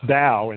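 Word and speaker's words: dao (道): That top character is the character that's pronounced dao in Chinese, which means the way.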